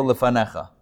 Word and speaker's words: lefanecha: A yud without a vowel in the middle of the word, I feel like I'm reading teleprompters, is a silent yud and remains unpronounced. For example, lefanecha.